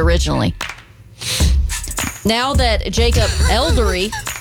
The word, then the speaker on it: Elordi: Are double dating with Timothy Chalamet and Jacob Elordi. – Elordi. You said it wrong.